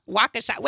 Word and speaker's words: Waukesha: I didn't say that right either. Waukesha. Why did we say Waukesha? Waukesha. Wait, is it Waukesha County? Yeah. Okay, there we go.